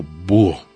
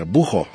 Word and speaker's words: búho: De buena palabra, por ejemplo, búho. Lleva H intermedia, recuerden. Sería bujo, si yo pronunciara y lleva acento en la U.